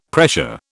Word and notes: pressure: British English

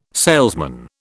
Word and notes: salesman: British English

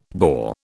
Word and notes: Boer: British English

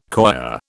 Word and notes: coir: British English

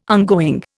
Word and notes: ongoing: Indian

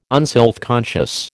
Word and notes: unselfconscious: American English

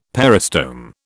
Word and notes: peristome: British English

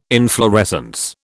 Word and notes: inflorescence: British English

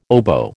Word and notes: oboe: American English

